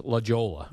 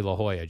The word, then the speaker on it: La Jolla: It looks like it should be pronounced La Jolla. – Yes. – But it's actually La Jolla, Jerry.